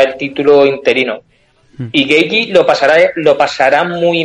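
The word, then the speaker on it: Gaethje: Eh, vale, hay que poner la boca como acento canario, Geiji.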